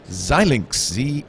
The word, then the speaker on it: Xilinx: He's from Xilinx. Is that how you pronounce it? – Yep, Xilinx.